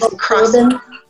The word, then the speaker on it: Corden: James Corden, how do you say his name? Corden? Or whatever, the talk show host. He does these Corden. – Yes, thank you.